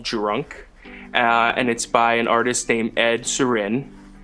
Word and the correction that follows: Sheeran: It's Ed Sheeran. You're pronouncing it pretty weird.